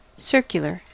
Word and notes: circular: American English

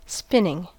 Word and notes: spinning: American English

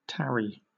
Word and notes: tarry: British English

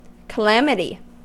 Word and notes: calamity: American English

